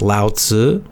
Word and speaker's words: Lao Tzu: Again, I cannot pronounce the Chinese correctly, but yes, Lao Tzu, L-A, in English letters, Latin letters, L-A-O, and then next is T-Z-U, usually it's how it's pronounced, Lao Tzu.